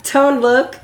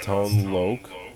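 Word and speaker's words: Tone Lōc: Tone Look. – Yes, and we just learned. You told me that it's pronounced Tone Loke, which I've been mistaking.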